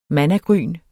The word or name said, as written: mannagryn